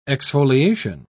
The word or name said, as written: exfoliation